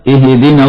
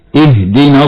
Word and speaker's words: اهدنا: ومن الخطأ هنا قلقلة حرف الهاء في كلمة اهدنا ناحية الكسر، فبعضهم يقرأ خطأ هكذا اهدنا الصراط المستقيم، هكذا خطأ مرة اخرى اهدنا الصراط المستقيم.